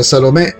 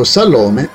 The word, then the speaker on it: Salomè: Grazie all'opera francese Salomè di Oscar Wilde, e alla successiva versione operistica di Richard Strauss, il nome ebraico Salome è diventato famoso in italiano con l'accento spostato sulla vocale finale.